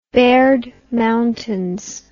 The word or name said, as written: baird mountains